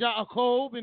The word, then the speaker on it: Yaakov: Just because they done said his name a little funny, his name is pronounced Yaakov in Hebrew, and now they're going to call him Yaqub and say that he made the white man and grafted the white man.